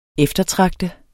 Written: eftertragte

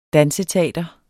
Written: danseteater

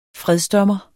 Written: fredsdommer

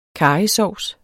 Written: karrysovs

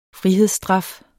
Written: frihedsstraf

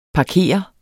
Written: parkere

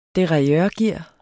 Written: derailleurgear

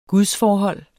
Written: gudsforhold

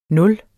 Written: nul

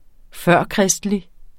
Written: førkristelig

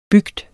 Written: bygd

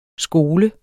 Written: skole

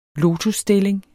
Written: lotusstilling

